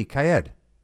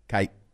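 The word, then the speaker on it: Kyed: It's K-Y-E-D. Kaid. – Kaid. Oh, I thought it was Doug Kaid. Oh, I could totally see how someone would think it was that way. – Kaid? – Kaid?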